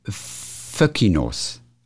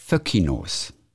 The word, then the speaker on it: Foenkinos: Also es wird wohl ausgesprochen Föckinos. Obwohl das N drin ist, Foenkinos, heißt es wohl Föckinos.